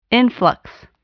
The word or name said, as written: influx